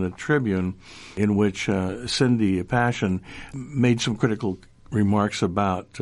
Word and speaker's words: Paschen: We should mention that the name of the challenger is Cindy Passion. – Yes, pardon me. I meant to say that. – Not spelled like Passion, but... – Yeah, P-A-S-C-H-E-N.